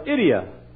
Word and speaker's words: idia: There was a Greek word originally pronounced, we'd call it idia. I-D-I-A, idia.